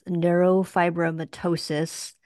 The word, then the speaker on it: neurofibromatosis: About 2 years ago, Will's son, Liam, was diagnosed with neurofibromatosis, NF.